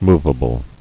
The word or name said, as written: Movability